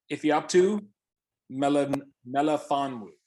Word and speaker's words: Ifeatu Melifonwu: It's all right. Ifeatu Melifonwu. Ifeatu Melifonwu.